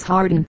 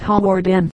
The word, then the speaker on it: Hawarden: and that it was Harden, not Hawarden.